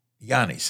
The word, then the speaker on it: Giannis: I was born in Greece. It's Greek for John. Giannis is his name. – Yeah. – And so, but the Americans pronounce this Giannis.